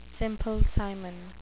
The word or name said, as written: Simple Simon